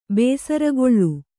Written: ಬೇಸರುಗೊಳ್ಳು

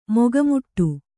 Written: ಮೊಗಮೋಟ

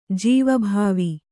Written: ಜೀವಭಾವಿ

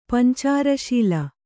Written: ಪಂಚಾರಶೀಲ

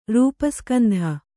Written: ರೂಪಸ್ಕಂಧ

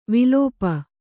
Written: ವಿಲಾಪ

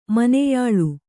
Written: ಮೊನೆಯಾಳು

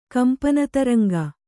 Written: ಕಂಪನತರಂಗ

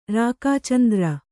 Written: ರಾಕಾಚಂದ್ರ

